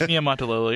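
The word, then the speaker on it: Niumatalolo: Then you have Paul Johnson at Navy, and when he goes to Georgia Tech, followed up by his protege, Ken Niematalolo. I hope I didn't butcher that. – No, it's Niematalolo. You're right.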